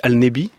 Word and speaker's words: Allenby: Allenby, en arabe, on le prononce assez vite Al-Nebi, c'est-à-dire le prophète.